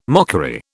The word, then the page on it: mockery: British English